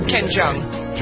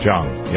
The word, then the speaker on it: Jeong: Ken Jeong, is that how you pronounce his name? I never Ken Jeong. – Jeong, yep.